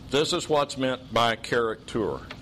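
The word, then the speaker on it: charaktēr: And it's pronounced character. It's where we get our word character.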